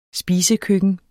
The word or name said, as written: spisekøkken